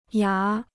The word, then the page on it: 牙: yá